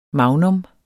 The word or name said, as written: MAGNUM